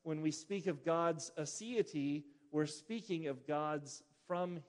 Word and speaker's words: aseity: The theological term is aseity. It is spelled A-S-E-I-T-Y, sometimes pronounced aseity.